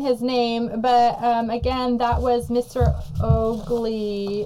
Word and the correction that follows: Ogles: or not Ogley. – Ogley Bogley. – Where did I put it? – Heather found that it is pronounced Mr. Ogles, O-G-L-E-S.